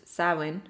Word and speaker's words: Samhain: The Halloween holiday has its roots in the ancient Celtic festival of Samhain, a Gaelic word pronounced Samhain, as I just pronounced it.